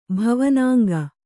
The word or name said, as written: ಭವನಾಂಗ